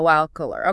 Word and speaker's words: alquiler: And to help us understand what's going on, we're going to be looking at this podcast from Spain called, hold on, let me make sure I pronounce this right. Como Locular? – Como alquiler. Okay, so that means like how to rent or something.